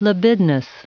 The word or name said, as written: libidinous